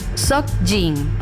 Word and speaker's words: Seokjin: Seokjin.